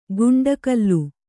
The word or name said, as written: ಗುಂಡಕಲ್ಲು